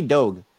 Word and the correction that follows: Doig: It's D-O-I-G. I don't know how to pronounce that. – Doig.